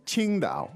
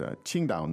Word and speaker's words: Qingdao: impress with your tones. Because it's Qingdao.